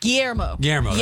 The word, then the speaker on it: Guillermo: How would you say that? – Guillermo. You messed me up in my head. Guillermo. – Yeah. – That's how you say it.